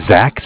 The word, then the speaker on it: Zach: My name is Zach, spelled Z-A-C-H. And I'm calling from the Bank of Cardiff. And Cardiff is spelled C-A-R, like car, mm-hmm.